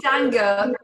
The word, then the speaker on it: Danger: It's actually Danger, German pronunciation.